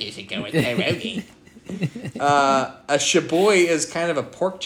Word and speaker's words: schabowy: Poland, national dish is called, it's spelled S-C-H-A-B-O-W-Y. So it sounds like shaboy. – Shaboy? – It's shaboy.